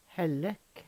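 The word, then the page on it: Hellek: Numedalsmål; Veggli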